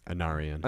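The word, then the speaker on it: Anárion: Anarion.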